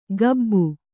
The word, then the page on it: ಕಬ್ಬು: kannada